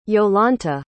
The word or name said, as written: yolanthe